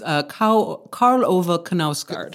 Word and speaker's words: Karl Ove Knausgaard: I think it's Karl Ove Knausgaard.